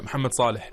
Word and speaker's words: Mohamed Salah: Hey, you pronounced it right, Mohamed Salah. – Oh? – Yeah, Mohamed Salah.